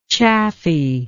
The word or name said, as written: chaffiness